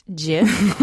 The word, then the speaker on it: GIF: Firstly, it's pronounced GIF.